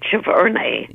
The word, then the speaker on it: Giverny: And in it, so do you pronounce it Giverny, the French way, or Giverny?